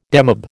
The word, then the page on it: demob: American English